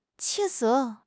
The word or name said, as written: 气色